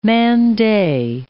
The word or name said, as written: man-day